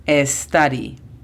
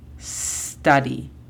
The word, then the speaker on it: study: La pronunciación fonética de study es S-T-A-D-I. Study. Tomen en cuenta que, como muchas veces hemos mencionado, no es study. Empezamos desde el sonido de la S. Study.